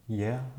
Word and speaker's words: Gaia: In Greek you have Gaia or Yea, as it's pronounced in Greek, and it's not a new thing in a way to perceive this as a being, almost as a entity.